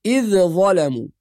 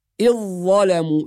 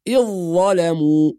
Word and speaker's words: إذ ظلموا: بعض الناس يقرأ بإظهار الذال والقلقلة أحيانا فيها، فنسمع البعض يقول إذ ظلموا، وهذا خطأ، والصواب أن الذال تضغم في الظاء، فنقول إذ ظلموا، يعني ننطق ظاء مشددة بعد الهمزة مباشرة، إذ ظلموا هكذا.